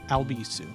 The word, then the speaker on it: Albisu: If you'd like to follow me on social media, you can find me at Alex Albisu. My last name is spelled A L B as in boy, I S as in Sam, U.